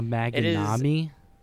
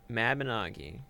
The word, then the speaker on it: Mabinogi: A Maginami? – Mabinogi.